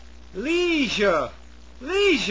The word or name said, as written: leisure